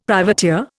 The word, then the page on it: privateer: Indian